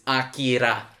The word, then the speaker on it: Akira: I mean, you're putting really too much emphasis on the I, because it's Akira.